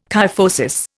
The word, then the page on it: kyphosis: Indian